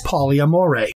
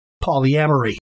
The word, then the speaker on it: polyamory: So it is probably because of that song that some people pronounce the word polyamory as polyamore. In general, though, the typical pronunciation is simply polyamory.